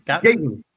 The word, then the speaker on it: Gaten: Gaten.